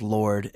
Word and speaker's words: Lorde: her name is pronounced Lorde and the E is silent.